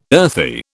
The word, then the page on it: earthy: British English